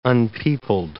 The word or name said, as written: unpeopled